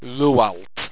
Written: wrualt

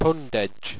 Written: pundedg

